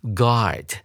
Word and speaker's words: guard: 紧接着，我们来看第二个单词 guard。注意到这个词的发音，它的 u 是没有发的哦。像在英文里面有一些类似的发音结构的单词，像是 guest，g u e s t，客人，它的 u 也是不发声的。